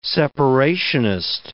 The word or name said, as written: separationist